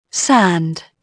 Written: sand

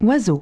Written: bird

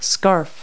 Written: scarf

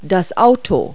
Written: car

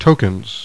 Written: tokens

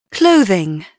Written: clothing

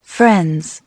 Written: friends

